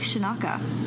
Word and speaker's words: Scinocca: I am here at Parkway Lanes this week and interviewing, it's Frank Scinocca. That is the Italian pronunciation, so right.